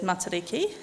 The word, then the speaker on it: Matariki: So, in Māori language, we refer to the star cluster as Matariki.